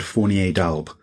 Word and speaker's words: Fournier d'Albe: How do you pronounce the name of the inventor of the optophone? – Yeah, so the guy was eclectic, to put it mildly. His name was Edmund Edward Fournier d'Albe, which, if you take the French, it's Fournier of Albe, which is a region of France.